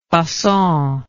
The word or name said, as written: facon